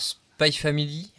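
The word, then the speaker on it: Spy×Family: Alors, je ne sais pas, le X, on prononce ou pas ? Est-ce que c'est Spy Family ou Spy Cross Family ? – Alors, Spy Family, Fred, il va être content.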